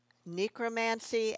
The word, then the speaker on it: necromancy: I say necromancy, but and that is the sort of the art of magic concerning the dead.